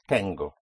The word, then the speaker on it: tengo: Tengo. It's almost like the tango which one dances in Argentina, but it is spelled with T-E-N-G-O.